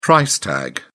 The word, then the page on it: price tag: British English